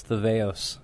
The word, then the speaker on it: Theveos: Is that the way you say it, Theveos, or what's the right way to say it? – That's the proper way. If you've been to Greek school, it's Theveos.